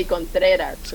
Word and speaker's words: Contreras: How to roll your R's better. – That's going to take a very so you can say Contreras.